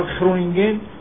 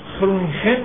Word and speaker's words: خرونینگن: بله دانشگاه به هر حال خرونینگن هست. خرونینگن باید باشه که خب دیگه تو فارسی خیلی ناجور میشه. من خرونینگن رو ترجیح دادم.